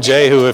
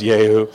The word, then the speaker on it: Jehu: You can call him Jehu if you want, but his name would be pronounced Yehu.